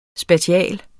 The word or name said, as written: SPATIALT